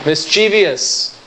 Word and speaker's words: mischievous: Mischievous. No. Don't let that I creep in there. It's a three-syllable word. Mischievous. Mischievous, not mischievous.